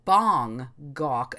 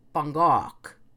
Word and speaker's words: Bangkok: It's kind of more like Bangkok, and it's a very short bang, if you will, and a longer gok. So it's like Bangkok.